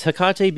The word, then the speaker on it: Hecate: That's how I pronounced it, just basically because of Tecate beer. Oh yeah, I went with Hecate. Well, I, it's Hecate because that's uh, the name of a Greek goddess.